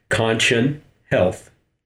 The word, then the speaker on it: Conchon Health: Oh uh, yeah, it's uh, it's a screwy spelling, but uh, the pronunciation is pretty simple. It's Conchon Health.